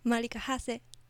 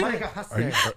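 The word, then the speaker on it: Marica Hase: Marika Hase.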